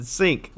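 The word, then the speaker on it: Cink: You always call him Kink. – Yeah, that's how you say it. – Sink. – Yeah, I like to call him Kink. So I call Sam Burns Sam Buns. Yeah, well, it's Stuart Sink, but I would always call him Kink.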